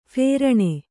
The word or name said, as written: ಫೇರಣೆ